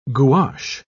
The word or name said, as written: gouache